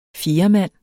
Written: fjerdemand

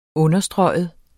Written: understryge

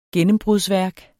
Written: gennembrudsværk

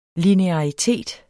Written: linearitet

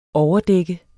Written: overdæk

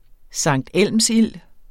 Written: sanktelmsild

